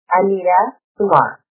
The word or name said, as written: anita kumar